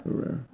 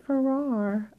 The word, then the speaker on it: Ferrer: Is it Ferrer? – Ferrer. – Oh, I always pronounced it Ferrer. I'm wrong.